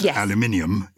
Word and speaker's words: aluminium: So it's spelt like magnesium and it's pronounced aluminium in the UK. Yes. But in America, it's aluminum and it's spelt like platinum.